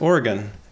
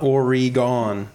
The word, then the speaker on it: Oregon: He was catching up on our episodes and he wrote in, Oregon is pronounced Oregon. Not Oregon.